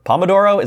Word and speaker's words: Pomodoro: Pomodoro is that? Am I yeah. saying that right? Yeah, okay.